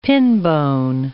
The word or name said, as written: pinbone